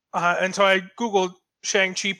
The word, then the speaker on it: Shang-Chi: has stated the official pronunciation of how it's supposed to be said, and apparently it is Shang Chi, which I mean, going to Ong is not too far of a leap, honestly.